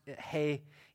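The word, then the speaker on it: hey (ה): And so then verse 33, we move into the, it says, if you have an English Bible, it probably says H-E, it's pronounced hey in Hebrew.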